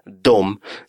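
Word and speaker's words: de: Грамматически правильная состоит всего из двух букв «d» и «э», а читается также «дом».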